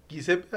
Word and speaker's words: Giuseppe: Giuseppe, así se pronuncia.